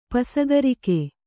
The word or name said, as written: ಪಸಗರಿಕೆ